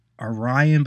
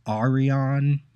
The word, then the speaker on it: Arion: Now I'm going to say right up front, Arion, some people I know call it Orion, but that doesn't make sense to me because there is an Orion in the New Gods and it's spelled with an A, A-R-I-O-N.